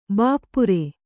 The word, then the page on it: ಭಪ್ಪರೆ: Kannada